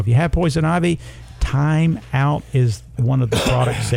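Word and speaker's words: Thyme Out: Now, we have a product called Time Out, but it's spelled T-H-Y-M-E because that is an herb.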